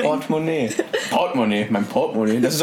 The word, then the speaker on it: Portemonnaie: Seit wann betont man die erste Silbe? Portemonnaie, das ist französisch.